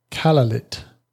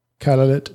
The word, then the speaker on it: Kalaallit: The Greenlandic Inuit language, it's called Kalaallit. Kalaallit Nunaat, which means land of the people. Kalaallit, that's K-A-L-A-A-L-L-I-T. Kalaallit. Something like that.